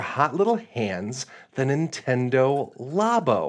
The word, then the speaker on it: Labo: It is Labo, like laboratory.